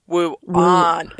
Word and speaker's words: Wuane: But yeah, it's spelled W-U-A-N-E. And I'm like, there's no way I'd be able to pronounce that. – Wuane is the normal girl. Wuane is the fancy, snooty girl. – It is Wuane.